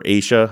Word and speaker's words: Asha: We're going to talk about the disappearance of, is it Asha or Aisha? How does she pronounce it? Aisha Degree.